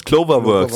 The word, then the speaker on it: CloverWorks: Clover, Clover? Clover spricht man das aus. Cloverworks.